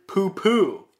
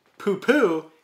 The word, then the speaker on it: Pupo: giving the correct pronunciation for Miguel's last name the same sound as the word poo-poo in English. But since most of the announcers and interviewers don't speak a lick of Portuguese, they wouldn't know of such a pronunciation, so they will usually give the Spanish way of pronouncing it, poo-po. So you referring to Miguel's name as poo-poo is the correct Portuguese pronunciation.